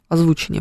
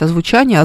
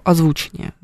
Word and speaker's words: озвучение: О, у меня есть на после 13 мая есть замечательные гости, там будет артист озвучения, там будет озвучение, правильно говорить, да. Хочется сказать озвучание, озвучки. Ну, озвучка, кстати, еще ничего. А вот озвучание нет, это ошибка. Профессионалы используют слово озвучение.